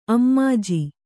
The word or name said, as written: ಅಮ್ಮಾಜಿ